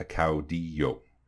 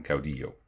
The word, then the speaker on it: caudillo: So it's pronounced caudillismo, and the leader is a caudillo. So caudillismo and caudillo.